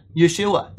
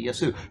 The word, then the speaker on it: Yesu: When you go to Japan, they call him Yesu. Pretty close to Yeshua, right? But it's not Yeshua. They don't say it. They say Yesu.